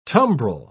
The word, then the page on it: tumbrel: British English